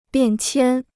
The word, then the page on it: 变迁: biàn qiān